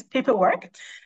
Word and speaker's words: Paper.Work: And it's an honest question around whether you're saying the name right, because to be honest, a lot of people are getting it wrong. But let me just clear that first. It's Paper.Work.